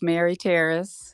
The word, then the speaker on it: Mary Taris: It's Mary Taris.